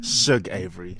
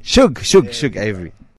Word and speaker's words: Shug Avery: Shug Avery. – Shug Avery.